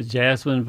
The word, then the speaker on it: jessamine: So the one that you have is actually Carolina jessamine, J-E-S-S-A-M-I-N-E, and we all pronounce it jasmine, but it's really spelled jessamine, so I'll pronounce it that way for right now.